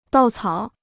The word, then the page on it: 稻草: dào cǎo